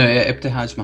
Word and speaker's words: Ibtihaj: Ibtihaj uh, Muhammad. If I oh, butchered yeah. that, I'm sorry. No, Ibtihaj Muhammad.